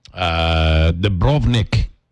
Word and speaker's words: Dubrovnik: Dubrovnik is how they say it.